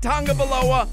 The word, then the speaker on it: Tagovailoa: Tua Tagovailoa pronounces his last name Tagovailoa. I'm sorry if you don't see the N in there. He pronounces it Tagovailoa. I pronounce it Tagovailoa.